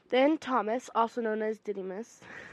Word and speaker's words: Didymus: By the way, Didymus was right, because I listen to my Bible also when I read it, and that's how they pronounce it.